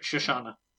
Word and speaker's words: Shoshana: Shoshana. – But the final, was it? Shoshana? – Shoshana.